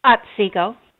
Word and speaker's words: Otsego: Is it Otsego, Minnesota? Otsego? – Otsego. – Otsego. Okay.